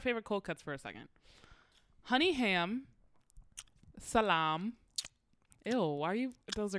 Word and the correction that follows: salami: That's not how you say it. Please don't disgrace me like that. – It's salami. Salami.